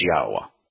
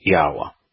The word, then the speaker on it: Yahweh: The most accurate pronunciation that we can have of Yod He Wah He is Yahweh. The Y is pronounced just as it is in English and in most languages. The two Hays are pronounced Ah. It's a vowel in Hebrew, and the W or Wa in Hebrew is pronounced like the English O. So it'd be Yahweh.